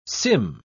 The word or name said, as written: Sym